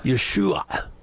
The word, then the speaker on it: Yeshua: proper pronunciation would be Yeshua. But again, most Americans can't make that last sound. They can do the SH, not the ayin.